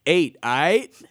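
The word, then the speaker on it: 8ight: I think the correct pronunciation, Travis, is eight. I.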